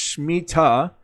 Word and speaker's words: Shemitah: The Hebrew word is usually pronounced Shemitah and spelled various ways in English, including Shemitah, and Shemitah.